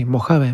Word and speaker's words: Mojave: Bueno, eso de Mojave, y se pronuncia así, Mojave, más allá del modo oscuro, me parece genial.